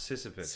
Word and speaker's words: Sisyphus: It's a P-H-U-S. – It's pronounced Sisyphus. – Sisyphus. – That's how it's pronounced.